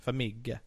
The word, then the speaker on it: Farmiga: Vera Farmiga. That's how you say her name. Farmiga.